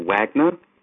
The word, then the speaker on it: Wagner: my name is Michael Wagner, although I was actually born Michael Lack, L-A-C-K, but since the age of about six, I've been Michael Wagner. That's Wagner, not Wagner, which is actually the correct German pronunciation. My parents went with Wagner just to keep things simple and to spend a lot less time telling people it's actually pronounced Wagner. So my name is pronounced Michael Wagner.